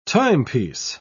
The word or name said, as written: timepiece